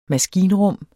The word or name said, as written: maskinrum